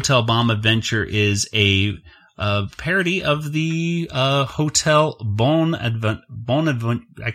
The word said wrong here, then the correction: Bonaventure: The Bonaventure. That's how you say it.